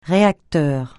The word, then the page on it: réacteur: francais